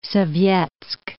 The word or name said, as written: Sovetsk